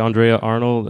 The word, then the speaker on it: Andrea Arnold: Uh, so, if you guys are not familiar, uh, directed by how you pronounce, uh, Andre Arnold. Uh, uh, just say Andrea Arnold, and then it's about a teenage girl with nothing to lose joins a traveling magazine sales crew.